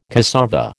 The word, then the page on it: cassava: American English